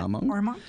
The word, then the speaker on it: Armonk: you moved to Armonk, New York. Did I pronounce that correctly? – Yep. Armonk.